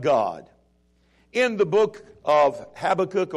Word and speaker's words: Habakkuk: In English, it's Habakkuk. In Hebrew, it's Habakkuk.